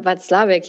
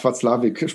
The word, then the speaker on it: Watzlawick: Watzlawick. – Ja, Paul Watzlawick, ja. Mhm. – Ach, Watzlawick spricht man den.